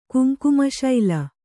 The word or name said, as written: ಕುಂಕುಮಶೈಲ